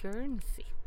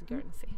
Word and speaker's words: Guernsey: Tulip Guernsey. – Jag får ursäkta liksom uttalet, men det är helt rätt. Du uttalas Guernsey.